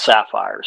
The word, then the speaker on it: Safir: spelled S-A-F-I-R, but it's pronounced Sapphire.